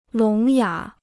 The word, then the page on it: 聋哑: lóng yǎ